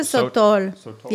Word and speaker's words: Sotol: So we want to move to, how do you say this? – Let's move to Sotol. Yeah, Sotol.